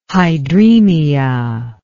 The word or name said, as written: hydremia